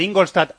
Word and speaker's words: Ingolstadt: Bueno, se adelanta el Ingolstadt. – Se adelanta el Ingolstadt al Bayern, ahora lo he dicho bien.